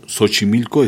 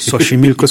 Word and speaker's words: Xochimilco: Por ejemplo, Xochimilco es con S. – Xochimilco es con S, sí. – Y se escribe con X.